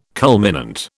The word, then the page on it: culminant: British English